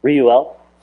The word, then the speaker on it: Reuel: Reuel. Sorry, it's three syllables, but it doesn't look like it should be three syllables.